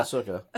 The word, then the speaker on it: Ahsoka: And then, Ahsoka. I said it right. Haha. – Yeah, Ahsoka.